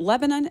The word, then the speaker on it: Lebanon: So there's a story, let me make sure I've got the right one, out of, is it Lebanon? Is that how we're pronouncing yeah.